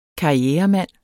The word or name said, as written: karrieremand